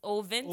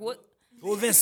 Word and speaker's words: Ovince: We do see Mr. Ervince Saint-Per, yeah. If I said that properly. – Yeah. Ovince. Well, we're going to have to work on these last name. – Ovince Saint-Per.